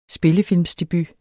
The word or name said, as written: spillefilmsdebut